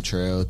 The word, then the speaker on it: Trell: Trail is like my name, Trail, T-R-E-L-L.